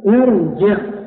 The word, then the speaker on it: ارجع: نقول ارجع، ارجع بالتكسير، لا نقول ارجع لأن الكسرة غير أصلية.